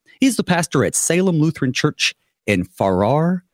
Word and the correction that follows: Farrar: And then also the town name, basically far. It's just, you know, not close, but far. We don't know which AR is silent.